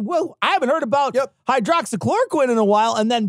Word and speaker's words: hydroxychloroquine: And it's her saying that, you know, COVID is the hydro, it's basically a plug for hydrochloroquine. – Right. – Or hydro, is it hydroxychloroquine? – I always say it wrong. Hydroxychloroquine.